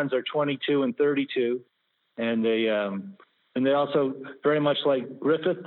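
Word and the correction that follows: Gryffin: Did I say that right? – Griffin, I think it's G-R-Y, and how do you spell it? Let me look. It's G-R-Y-F-F-I-N.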